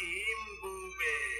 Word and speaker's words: Mbube: The song was originally hit in South Africa in 1939 for its writer Solomon Linda under its original title Mbube, pronounced Imbube, which means lion.